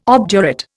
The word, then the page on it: obdurate: Indian